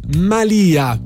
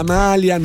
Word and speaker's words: malia: La malia di una strega, non la malia, non la maglia.